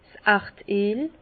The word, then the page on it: s' Erdeel: Alsatian; Bas-Rhin; Herrlisheim